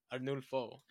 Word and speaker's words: Arnulfo: To be honest with you, like, this was the first time I've ever seen this name, but it's definitely Spanish because there's other pronunciation of how he says it, so... Arnulfo. Like Arnulfo.